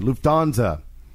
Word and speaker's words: Lufthansa: Lufthansa. – Lufthansa. – Lufthansa. – Lufthansa. Okay. Yeah. – Pan Am 727 flight waiting for start clearance at Munich overheard the following. Lufthansa.